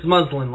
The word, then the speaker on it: Muslin: It's muslin, like the fabric, but good enough for a first try.